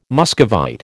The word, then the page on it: Muscovite: American English